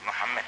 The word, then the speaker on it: Muhammed: Ahmet'i söyleriz de Mehmet'i söylerken Mehmet deriz. Halbuki Muhammed deriz. Biz. Muhammed yazı da aynandır. Fakat telaffuzu hatalı konuşulur.